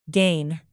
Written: gain

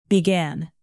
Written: began

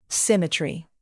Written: symmetry

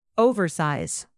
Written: oversize

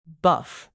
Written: buff

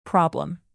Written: problem

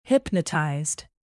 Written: hypnotized

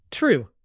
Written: true

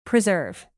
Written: preserve

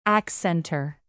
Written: accentor

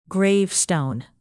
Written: gravestone

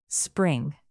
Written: spring

